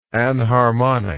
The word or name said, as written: anharmonic